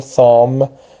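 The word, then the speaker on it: thumb: لغت بعدی سام، بیش تلفظ نمیشه، سام، انگشت، انگشت شست، سام،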